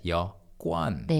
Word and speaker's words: 여권: You write it as 여 and 권, but you pronounce it always as 여권.